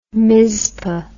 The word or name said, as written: mizpah